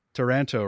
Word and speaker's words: Taranto: My mother-in-law's wartime boyfriend was an observer on the 1940 Taranto raid. Did I say that right? – Yeah, you said that right.